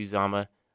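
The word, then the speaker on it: Uzomah: They lose CJ Uzama. See, Jeffrey, I said it right this time. Everyone, always the Bengal fans would always talk about how, and Auburn people would pronounce how I said his name wrong, but not anymore.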